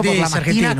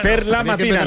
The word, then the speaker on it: per la mattina: Es buongiorno por la mattina. Que decís siempre. – Buongiorno por la mattina. – Per la mattina, no por la mattina.